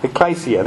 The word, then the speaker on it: ecclesia: It sort of depends where you went and how you learned to pronounce it. But yeah, ecclesia, that's how I learned it.